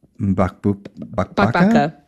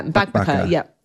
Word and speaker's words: Mbakbaka: There's another recipe, and boy, I'm not confident that I will pronounce this correctly, but it's the Wanpan Mbakbaka. – Yeah, Mbakbaka. Yeah, that's it. You did well. That's it. Mbakbaka.